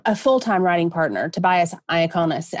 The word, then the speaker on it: Iaconis: I always want to call him Iaconis now because my telephone calls him, you know, my phone pronounces his name phonetically. But one of these days I'm going to literally say his name wrong. But it's Tobias Iaconis.